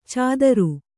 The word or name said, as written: ಛಾದರು